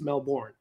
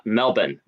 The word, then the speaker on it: Melbourne: My whole life, I thought it was Melbourne, but it's actually, if I wanted to be Australian and seem like I fit in, how do you pronounce it? – Melbourne. The R is silent.